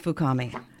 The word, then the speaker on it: Fukami: And it's the porcelain sculpture of Sue Harumi Fukami. Did I say it right? – That's pretty close. – But he's known basically as Fukami.